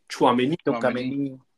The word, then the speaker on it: Tchouaméni: Tchouaméni.